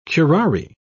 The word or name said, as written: curare